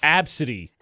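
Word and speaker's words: Abcde: But it's pronounced Absidy. – Absidy, yeah.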